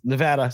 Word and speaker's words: Nevada: It's Nevada. Say it right, please.